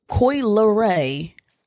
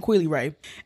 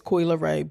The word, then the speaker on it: Coi Leray: But I listened to Coi Leray. I have to say Coi Leray because I was saying her name wrong. I was saying Coi Leray. And my brother was like, it's Coi Leray. But when you see L-E, you think like Leroy, Lee, like...